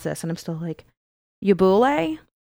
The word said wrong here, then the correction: Ubuhle: Is the name of the exhibit. It's Ubuhle.